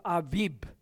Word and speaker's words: Abib: Now that is a month referred to in the scriptures, and in particular in Deuteronomy, it's referred to as the month of Aviv. It might even be pronounced Aviv by some, but it's A-B-I-B, Abib.